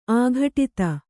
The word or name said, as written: ಆಘಟಿತ